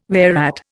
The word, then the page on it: whereat: Indian